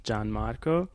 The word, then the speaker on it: Gianmarco: Like, not everyone sees my name and realizes that it's pronounced Gianmarco, but what I notice is that people are often more willing to learn how to pronounce it.